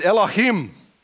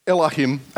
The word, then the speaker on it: Elohim: But in Hebrew, the word God here is Elohim. And it's pronounced pretty much the same in, English as in Hebrew without perhaps so much spit.